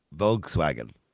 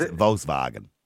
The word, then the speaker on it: Volkswagen: Volkswagen. We're in Europe. We're not in Germany. If you're in Germany, it's Volkswagen.